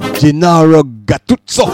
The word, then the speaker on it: Gennaro Gattuso: Smudge is giving me a pronunciation. Gennaro Gattuso. Man, I say it like him, like in come from Roma.